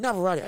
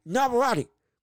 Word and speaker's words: Navarrete: And the reason was it was because Emmanuel Navarrete volume, his uh, excuse me, Navarrete, I keep getting his name wrong. Navarrete.